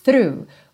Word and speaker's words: through: through. O GH Fica mudo aí, ok?